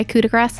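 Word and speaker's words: coup de grâce: It's French, so you have to pronounce the last syllable or last consonant before the last coup de grace. – Vowel. – Because there's an E on it.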